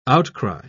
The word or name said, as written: outcry